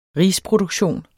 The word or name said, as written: risproduktion